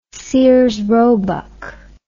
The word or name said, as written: Sears Roebuck and Co